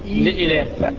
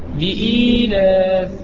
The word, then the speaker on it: إيلاف: لا لا انت قلت لي في اصل همزه وفي ياء انا عايز النطق الصحيح. – لإلاف إيلاف.